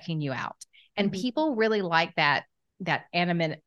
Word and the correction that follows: anonymity: How do you say that? – Anonymity. Yep.